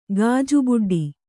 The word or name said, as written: ಗಾಜುಬುಡ್ಡಿ